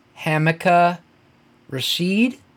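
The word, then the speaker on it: Hamilcar Rashed: It's H-A-M-I-L-C-A-R R-A-S-H-E-D Jr. Wow. – But I guess it's pronounced Hamica Rashid Jr., but come on.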